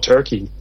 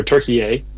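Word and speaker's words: Türkiye: and just learn a ton about Turkey, or Turkey-A. Mm-hmm. As the proper pronunciation, and Dave's just a great guide, and it's a lot of fun.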